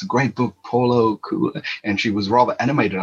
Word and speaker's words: Coelho: She said, say that name again, and I said it again, and she laughed and says, no, it's Coelho.